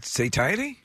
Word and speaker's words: satiety: And Penn State University researchers say that it's due to a sensory-specific satiety or satiety. I'm not sure how you pronounce this. – As in satiate? – I guess so. S-A-T-I-E-T-Y. – So satiety? – Yeah, I looked up the pronunciations. Yeah. It's satiety.